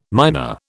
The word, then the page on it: myna: British English